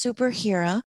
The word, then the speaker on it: SuperherA: All of this has inspired you to start this wonderful sports clothing company called SuperherA, right? Am I saying that properly? – Yes, you are. – So it's spelled SuperherA and it's pronounced SuperherA.